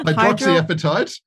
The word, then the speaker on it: hydroxyapatite: Hydroxyapatite. I don't know. – Hydroxyapatite. – Thank you. Hydroxyapatite. There is an appetite in there. I knew it. Hydroxyapatite.